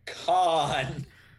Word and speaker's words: Kahn: I believe pronounced Kahn. – Madeline Kahn!